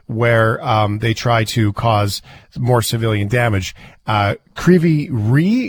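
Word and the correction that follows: Kryvyi Rih: is that how you pronounce it? How do you say the name of the city that the dam is that got hit with rockets? – No, it's called Krivi Rig. – Oh, boy. I missed by a long shot.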